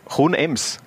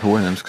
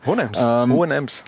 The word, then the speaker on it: Hohenems: 72 Tore in 119 Partien für Hohenems. Habe ich das jetzt richtig ausgesprochen? – Ich hätte Hohenems gesagt. Aber, Hohenems.